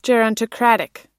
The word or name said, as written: gerontocratic